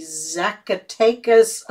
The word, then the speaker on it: Zacatecas: One of them which was photographed, Bonilla saw through the telescope of the Zacatecas Observatory in Mexico on August 12th of 1883. – Well done on that pronunciation, by the way.